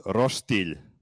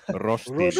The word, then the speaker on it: roštilj: Roštilj. – First of all, you said phonetically and then you put a roštilj. G in there. That is not how you would pronounce this. Roštilj.